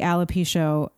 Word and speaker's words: alopecia: Now, how old were you when the alopecia? – Yeah. Alopecia. You're saying it right.